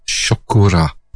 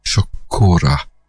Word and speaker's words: Chocolat: No, she pronounces it Chocora. Chocora. Chocolat, Chocora. Yeah, it's trying to say Chocolat in a Japanese accent.